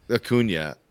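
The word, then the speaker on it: Acuña: Acuna. Acuna.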